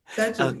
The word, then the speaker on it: Tadjo: So, Barani, so that people who are like listening, your last name is spelled T-A-D-J-O, Tajo, right? – Yes, Tajo.